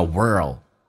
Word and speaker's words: world: in 跟 the 可以说成 in the，也可以说成 in the，th 去跳就变成 biggest in the，biggest in the，world 这个单词很难发，首先重度卷舌，然后再发 l 这个发音，l 这个发音是舌尖抬起来靠向你的上齿龈，叫 o，卷舌 world，world， which country is the biggest in the world？